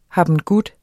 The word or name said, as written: habengut